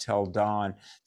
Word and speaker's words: Tel Dan: I mean, there's an evidence of Joshua at a place called Tel Dan, but it's pronounced Tel Don.